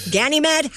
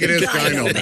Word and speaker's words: Ganymede: G-A-N-Y-M-E-D-E. Ganymede? How do you say that? – Ganymede. – No, it's not Gyno Med.